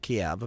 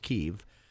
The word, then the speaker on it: Kyiv: Yeah, we always pronounce it Kiev. Apparently it's Kyiv.